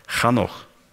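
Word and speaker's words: ханох (חנוך): Имя Енох на иврите произносится «ханох».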